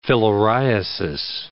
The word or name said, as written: filariasis